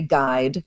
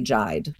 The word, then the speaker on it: guide: When we say guide, the only thing the U is doing is that diacritical role of making us certain that we shouldn't say jide, right? We say guide, we don't say jide.